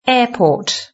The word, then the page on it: airport: British English